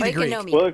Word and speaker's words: Oikonomia: All right, talk to me about the Okonomia Network. I can't even do it. I tried to, give me Oikonomia. – The Greek.